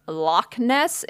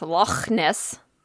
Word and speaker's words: Loch Ness: I got told that it's not pronounced Loch Ness. It's pronounced Loch Ness.